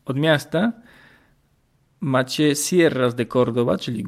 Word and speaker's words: Córdoba: I rzeczywiście, jeśli wybierzecie się do, Kor do Kordoby, Kordoby, bo Kordoba ma akcent na O.